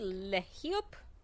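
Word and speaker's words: LeHeup: So this was the final issue of Shirtless Bear Fighter, issue 5, written by Jody Lehiop. Yep, that's how it's pronounced.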